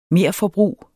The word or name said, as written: MERFORBRUG